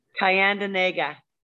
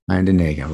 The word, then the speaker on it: Tyendinaga: Tyendinaga. – Tyendinaga, right on.